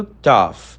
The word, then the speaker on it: tough: A pronúncia do tough não tem nada a ver com dull. Você não precisa colocar a língua entre os dentes.